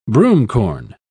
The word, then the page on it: broomcorn: British English